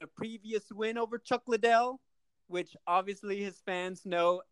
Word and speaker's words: Liddell: There are the things that people have been noticing, mispronouncing Liddell as Lydell, even now.